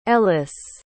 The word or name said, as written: ellice